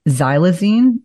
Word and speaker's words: xylazine: And I think I'm on a fentanyl curiosity focus at this point, but xylazine, am I pronouncing that properly? – Oh, yeah, absolutely. So it's crazy,